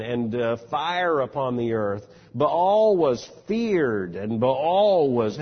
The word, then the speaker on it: Baal: And we've seen some of them named in particular, Baal, B-A-A-L, in the American South, routinely referred to as Baal. No one in the land would have recognized Baal. It was Baal. He pronounced both of the vowels.